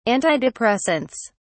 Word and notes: antidepressants: British English

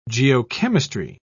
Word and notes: geochemistry: British English

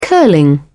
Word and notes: curling: British English

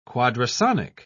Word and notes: quadrasonic: British English

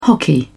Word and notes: hockey: British English

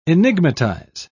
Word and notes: enigmatize: British English

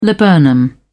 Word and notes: laburnum: British English

